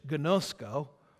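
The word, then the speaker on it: gnosko (γινώσκω): This is the Greek word here, and it is pronounced in English, gnosko, and what it literally means, know God, to learn to know a person through direct personal experience.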